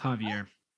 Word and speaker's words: Xavier: Javier. It's Javier. – Ooh. Oh, what? – It's Javier Arriaga. – It's an X. – Yes, and it is pronounced Javier.